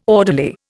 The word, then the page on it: orderly: Indian